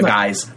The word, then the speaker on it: guise: Martha becomes a guide for the psychopomp. Martha becomes a guise, not a guide. She is a guide, but I'm not pronouncing my consonants well today.